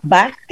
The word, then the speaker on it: Bach: Bueno, el bueno, que lo estoy pronunciando mal porque yo lo digo como si fuera alemán, es Bach. CH en alemán es como una G.